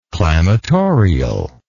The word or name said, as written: clamatorial